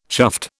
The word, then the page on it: chuffed: British English